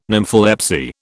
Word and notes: nympholepsy: British English

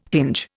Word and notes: hinge: Indian

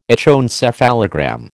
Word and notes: echoencephalogram: American English

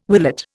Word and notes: willet: Indian

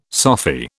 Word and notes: Sophy: British English

